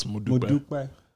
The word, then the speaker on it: Modupe: And in Yoruba is Modupe.